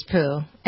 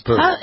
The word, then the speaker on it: Pugh: She's not responsible for who Harry Styles is dating, or Florence Pooh, is that how you pronounce her name, like Winnie the Pooh? – I think it's Pooh. It's Pooh. It's Pooh. – Spelled P-U-G-H.